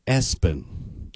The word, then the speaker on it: ESPN: His family pronounce it, though, Espen. Espen, yes.